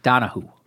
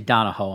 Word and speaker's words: Donahue: How do you pronounce it? – Donahue. – Okay. I always say Donahoe, I'm sure, which is just based on her spelling, it's just a horrible job by me, and I deserve to get all the hits on the head for that.